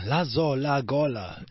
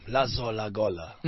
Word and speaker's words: Lazola Gola: It's got the rhyming scheme, Lazola Gola.